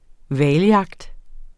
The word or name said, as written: hvaljagt